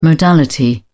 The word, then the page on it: modality: British English